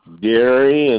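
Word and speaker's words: Garry: Gary, and the Gary is spelled with two R's, Hodgins, Hodgins.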